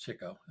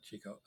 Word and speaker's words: Chico: I think it's actually pronounced Chico, not Chico.